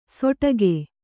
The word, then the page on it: ಸೊಟಗೆ: soṭage